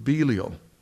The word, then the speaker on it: Belial: What harmony has Christ with Belial? That's how you pronounce it, I found out.